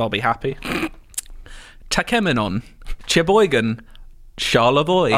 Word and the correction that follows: Cheboygan: Oh, well, you've already got one wrong. It's Sheboygan.